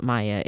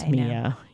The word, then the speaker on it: Mia: And very rarely do people mispronounce my name. And so for me, I feel like I'm doing a justice to the world and being like, not Maya, it's Mia.